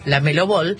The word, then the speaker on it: LaMelo Ball: LaMelo Ball. Se dice así, LaMelo Ball. Sí. O Ball.